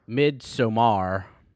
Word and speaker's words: Midsommar: How do you say it, buddy? – Midsommar. – Gotta hit the R.